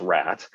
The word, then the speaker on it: RAT: And there's actually a process that a lot of folks call, it's RAT, pronounced RAT, and it's the riskiest assumption test.